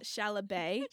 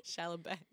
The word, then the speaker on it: Chalamet: First of all, I'll say his name right in my house. – Chalamet. – Chalamet.